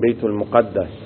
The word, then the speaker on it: بیت المقدس: میگیم بیت المقدس و نمیگیم بیت المقدس، تلفظ صحیح این کلمه بیت المقدس.